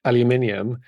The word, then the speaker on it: aluminium: What about A-L-U-M-I-N-U-M? – Well, trick question, not a word in English, so in British English, because we actually do spell it with the extra I in, so we say aluminium. – Oh, that makes sense. I always wondered about that, because there's phonetically, if you look at it, it's like, why are they saying, they're adding an extra syllable in there, but if you spell it differently, then that would make sense.